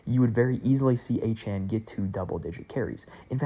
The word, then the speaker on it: Achane: Devon Achane, or Achane, I guess I believe it is technically pronounced, for the Dolphins, has been really, really good when he has gotten to double-digit carries.